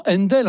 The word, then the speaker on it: Handel: Qu'est-ce que c'est que ce E rajouté ?» Händel, H-A-N-D-E-L, ça se prononce tout naturellement Händel en anglais.